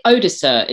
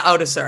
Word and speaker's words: Odoacer: Is it Odoacer? Is that how you say his name? – Yeah, Odoacer, I think.